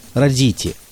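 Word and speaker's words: Roditi: Именно Родити, так он сам произносит собственную фамилию.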